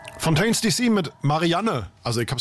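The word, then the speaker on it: Fontaines D.C.: Und du hast es wirklich falsch ausgesprochen, ja, weil es ist Fontaines DC.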